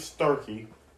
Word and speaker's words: Starkey: Might as well just tell you, my last name is Starkey. Yes, it's spelled star, as in the thing in the sky, and the key that you would put in a door.